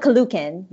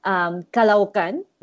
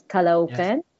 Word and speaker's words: Caloocan: First of all, I just want to say I found out how to actually pronounce the hometown that she's from, and it's not Caloocan, which I did say in the first episode, and first or second, I believe. So I apologize for that. It's actually Kalaukan. And Lon, maybe I'm wrong here, but there is a separation between the two O's. So it's Kalaukan.